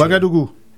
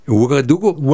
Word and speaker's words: Ouagadougou: Hovedstaden, der også er landets største by, hedder, og det kan jeg ikke udtale, Ouagadougou. – Ouagadougou.